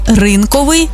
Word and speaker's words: ринковий: Отже, цілодобовий та щодобовий, 100-відсотковий, ринковий, одноразовий та багаторазовий, наскрізний та вишиваний.